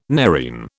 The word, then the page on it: nerine: British English